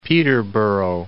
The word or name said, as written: Peterborough